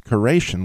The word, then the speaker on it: keration: And it actually says, I believe it's probably pronounced Keration, which is K-E-R-A-T-I-O-N, which actually, if you take the literal translation of that, it's carob pods.